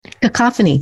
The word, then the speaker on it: cacophony: The word that I've been using the last couple of weeks, I think I told you this, and I don't know how to pronounce the word. So there's that. Cacophony. – Cacophony. You pronounce that beautifully.